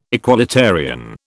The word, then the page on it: equalitarian: British English